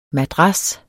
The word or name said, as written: madras